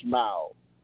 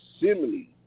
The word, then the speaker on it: simile: It's not smile. It's simile.